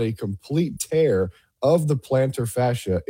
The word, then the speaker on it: plantar fascia: PJ Locke tore his plantar fascia.